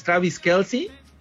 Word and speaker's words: Travis Kelce: Y la nota que se lleva también las portadas, Don Diego, es el Swiftie mayor, que es Travis Kelce, este esposo de Taylor Swift.